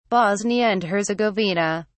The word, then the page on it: bosnia and herzegovina: British English